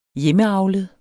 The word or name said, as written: hjemmeavlet